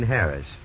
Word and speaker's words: Harris: I'm pretty sure he pronounced it just plain Harris. You know, like Harris Tweed.